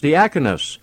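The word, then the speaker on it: diakonos (διάκονος): Now, the Greek word is diakonos, and that is D-I-A-K-O-N-O-S is the Greek word for it, pronounced diakonos.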